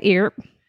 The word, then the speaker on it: Earp: Is it Earp? – Keep going. Yeah. – I wanted to pronounce it Earp. – Please just keep doing that. Yeah, that's right. I think that's right. Yeah. It's Wyatt Earp.